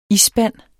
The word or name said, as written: isspand